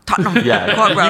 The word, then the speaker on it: Tottenham Court Road: Tottenham Court Road. – Tottenham Court Road.